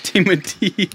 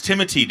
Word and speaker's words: Timothée: No, they pronounce it. Timothy. – I'm out. Okay, so Timothy Chalamet. – It's Timothy, dude.